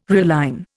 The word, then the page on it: realign: Indian